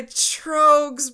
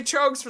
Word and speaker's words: Troegs: And not much pairs as well with this episode as the unseasonable Master of Pumpkins by Trogues Brewing. – Oh my God, you said it right.